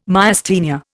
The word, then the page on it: Myasthenia: Indian